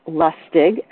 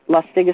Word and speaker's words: Lustig: Lustig. Lustig is spelled L-U-S-T-I-G, M-D-M-S-L.